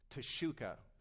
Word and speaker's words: Teshuka: Teshuka rhymes with bazooka, all right?